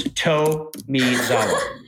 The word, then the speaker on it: Tomizawa: I said your name wrong, and it's Tomizawa.